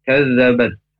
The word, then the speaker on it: كذبت: Kazabat huruf kaf, itu makhrajnya pangkal lidah agak ke depan dan hamz ya, ada desisannya, jadi bacanya itu kaf.